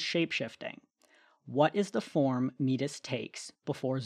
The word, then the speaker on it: Metis: I really do hate calling her Metis, but that is apparently the correct pronunciation.